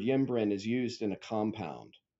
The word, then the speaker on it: ymbren: which is spelled Y-M-B-R-E-N, ymbren.